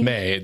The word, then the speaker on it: Mei: Maylee? May Maylee? Is that? – I don't remember now. – How you pronounce it? Mei. – Mei.